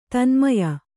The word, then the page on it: ತನ್ಮಯ: Kannada